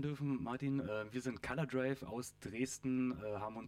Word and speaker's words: Color Drive: Und zwar ist das die Band Color Drive, aber äh, war jetzt richtig ausgesprochen. Color Drive, genau.